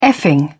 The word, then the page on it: effing: British English